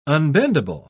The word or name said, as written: unbendable